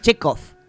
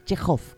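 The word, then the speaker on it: Chekov: Que no es Chekhov, es Chekhov con J.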